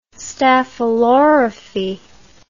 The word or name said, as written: staphylorrhaphy